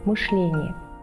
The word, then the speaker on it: мышление: Так, в конце 90-х годов допустимы были оба варианта произнесения – мышление и мышление. В современном же словаре дается только одна форма – мышление.